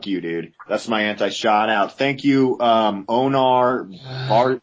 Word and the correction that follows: Onar Barjor: I mispronounced his name, but he's a member of the posse, Onar Barjor.